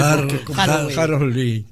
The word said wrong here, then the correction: Halloween: Halloween, sí.